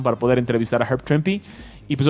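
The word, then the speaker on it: Trimpe: ¿Se pronuncia Trimpy? – Ajá.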